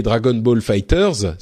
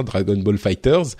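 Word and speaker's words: Dragon Ball FighterZ: Euh, et en parlant de succès de ce début d'année, euh, il faut bien sûr évoquer Dragon Ball Fighters, c'est la, la prononciation officielle, hein, Dragon Ball Fighters, euh, qui donc qu'on avait déjà euh, évoqué il y a quelques épisodes, mais hum, qui là donc est évidemment sorti.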